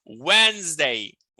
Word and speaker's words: Wednesday: Olha a pronúncia. Wednesday, Wednesday, Wednesday. É como se a gente não tivesse esse D, o primeiro. Né? A gente vai eliminar ele.